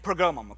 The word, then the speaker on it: Pergamum: The church of Pergamum, there it is, and then Thyatira and Pergamum, okay? So I said it right.